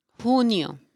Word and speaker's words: junio: Junio.